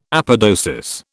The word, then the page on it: apodosis: British English